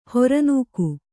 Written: ಹೊರನೂಕು

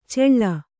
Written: ಚೆಳ್ಳ

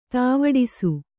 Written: ದಾವಡಸು

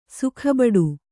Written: ಸುಖಬಡು

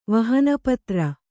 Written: ವಹನಪತ್ರ

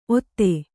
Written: ಔತಿ

